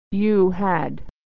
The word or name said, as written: you had